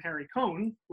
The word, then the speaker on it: Harry Cohn: Yeah, so he was a shoo-in for this one, and he went to the president of Columbia Pictures at the time, Harry Cohn, who I think we talked about. – And we said his name right this time.